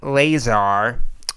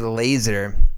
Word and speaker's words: Lazar: Yeah, Bob Lazar. I want to say laser, but that's not how you pronounce it. But it's actually Lazar.